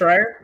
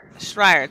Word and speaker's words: Schreier: Well, it's could, but Jason Schreier, I think that's how you pronounce his name. – Mm-hmm. – I think it's just Schreier. – Schreier, thank you.